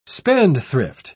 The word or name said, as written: spendthrift